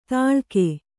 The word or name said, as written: ತಾಳಗೆ